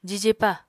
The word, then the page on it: jijepa: apatani